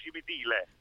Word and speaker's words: Cimitile: Sì, di Cimitilla, provincia di Napoli. – Cimitilla. – Guarda, l'accento Cimitilla. Non si direbbe, no, no, bravo. – No. Bravo. – Nord-est. – Ex cimitero di Nola, quindi coemeterium Cimitile.